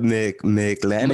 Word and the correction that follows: McLaughlin: McLaughlin. – Catholic? – McLaughlin. Yeah.